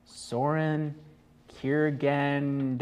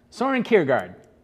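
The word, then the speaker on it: Søren Kierkegaard: Soren Kierkegaard. I butchered that. – A Danish philosopher. – Soren Kierkegaard. Kierkegaard. – What is it? Kierkegaard. – Soren Kierkegaard. – There we go.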